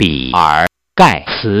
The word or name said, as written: gates